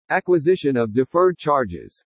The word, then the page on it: ACQUISITION OF DEFERRED CHARGES: English